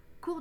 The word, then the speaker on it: cours: Cours de français, cours d'anglais, cours d'espagnol, cours de mandarin et cours de yoga. Cours s'écrit avec un S au singulier et au pluriel que l'on ne prononce pas.